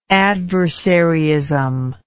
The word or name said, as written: adversaryism